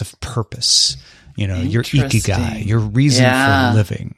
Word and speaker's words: Ikigai: All right, let's get to my first one, which is Ikigai, and I'm not saying it wrong. That's how they said it on the Economist podcast where I first heard about it.